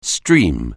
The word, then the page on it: stream: anglais